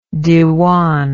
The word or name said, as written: diwan